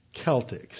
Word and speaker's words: Celtic: There was no sibilant C sound in ancient Greek. There's a K sound, ka, but no Celtic sound. It was always Celtic. So that's the correct pronunciation, if you care to be correct.